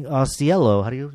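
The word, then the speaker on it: Ausiello: And I was just reading Osiello, is that how you say his name? – Mm-hmm. – Osiello at TV Guide.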